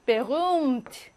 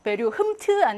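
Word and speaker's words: berühmt: 베룸트, 베류흠트 아니고 흐는 발음하지 않고 베룸트 하고 앞에 있는 모음을 길게 발음하고요.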